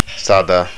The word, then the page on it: sada: Estonian